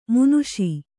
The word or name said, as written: ಮಾನುಷ